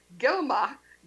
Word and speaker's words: Geiogamah: Of course, our guests this week have been Hanay Geiogamah. Sorry, it's spelled differently than it's pronounced.